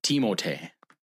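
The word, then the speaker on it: Timothé: No, Timothy Luwawu. – That's wrong. – Cabarro. – It's Timotei.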